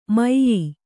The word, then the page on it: ಮೈಯೀ: maiyī